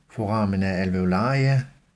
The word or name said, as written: foramina alveolaria